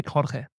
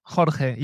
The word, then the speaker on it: Jorge: Yeah, Jorge. I would say Jorge, but it's a uh, it's it's ha, Jorge. Yeah, Jorge. But it's it's hard to pronounce for for, for English speaking people.